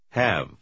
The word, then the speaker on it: have: Запомните, что в конце второго слова буква «и» не читается. – We have to leave. Have.